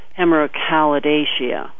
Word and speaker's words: Hemerocallidaceae: or the family Hemerocallidaceae, I believe is the way they pronounce it.